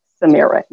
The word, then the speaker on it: Samira: It rhymes with Amira. Samira, you're perfectly.